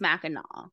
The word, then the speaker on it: Mackinac: So it's spelled like Mackinac Island, but it's pronounced Mackinac.